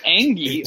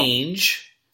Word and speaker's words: Ainge: She probably could have negotiated him up had she known, at least known not to pronounce it Ainge.